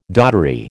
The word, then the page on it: doddery: American English